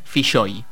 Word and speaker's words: Filloy: Se escribe Filloy, F-I-L-L-O-Y, y se pronuncia Filloy. Y él siempre decía, se pronuncia Filloy porque es gallego, no es irlandés. Esto es lo primero que hay que saber. Juan Filloy. Así, con esa shh.